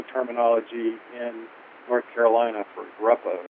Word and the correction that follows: grappa: It's Grappa, G-R-A-P-P-A, Grappa.